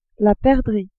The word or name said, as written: perdrix